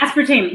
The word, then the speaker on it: aspartame: And when they brought her to the doctor, they told her it was the effect of the aspartame. Is that how you pronounce it? – Yeah, aspartame. Yeah, it's the sugar substitutes.